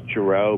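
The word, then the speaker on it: Gerow: But it is Giroux, but a lot of people say Giroux.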